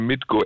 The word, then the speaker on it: Mitko: Und irgendwann klappt es auch, wenn wir meinen Namen richtig aussprechen können bei Fritz. Ich heiße Mitko, M-I-T-K-O. – Oh mein Gott, Mitko. Ja. So ist richtig.